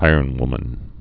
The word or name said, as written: ironwomen